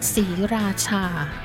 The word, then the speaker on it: Sriracha: Sriracha. Even we spell it up S-R-I, but the R is not pronounced. It's something in Thai language that we call is a false cluster.